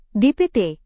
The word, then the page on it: dpt: Indonesian